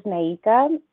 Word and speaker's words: Naïka: My name is Naïka,